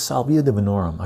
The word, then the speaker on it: Salvia Divinorum: And she goes, it's Salvia Divinorum.